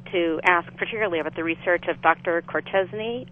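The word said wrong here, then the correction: Courchesne: I believe it's pronounced in... – Corshane, sorry, mm-hmm.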